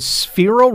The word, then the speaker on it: Sphero: You also have a tech toy called Sphero Robot.